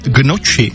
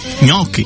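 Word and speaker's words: gnocchi: A taky Marťa, ahojda, tak mě tahá za uši, když někdo řekne, že by si dal gnoči. Správně je ňoky.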